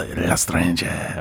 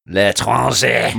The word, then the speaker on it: L'Étranger: Well, as the resident atheistic scum, I'm going to give them the L'Estranger, or however you say, how do you say it? – L'Étranger. – L'Étranger.